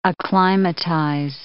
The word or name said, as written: acclimatize